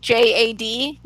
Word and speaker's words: JAD: And apparently, so I thought that everyone pronounced it J-A-D, you know, Jarrett Anderson Dolan, but apparently the coaches call him Jad, which I don't like.